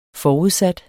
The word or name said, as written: forudsat